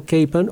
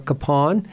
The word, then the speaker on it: Kapaun: And especially the first Sunday in June and Veterans Day, they have a very special commemorative for Father Emil Kapaun or Kapaun, depends on whether you're German or Czech, how you pronounce it.